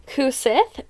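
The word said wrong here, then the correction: Cù-sìth: which is the black dog. – Is it the Cushy? – Oh, probably. Thank you.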